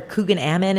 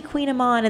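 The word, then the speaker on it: Kouign-amann: Or Coogan Ammon, and our staff was like, oh, it's actually Queen Amon.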